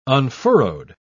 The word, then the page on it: unfurrowed: British English